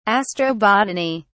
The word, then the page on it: astrobotany: British English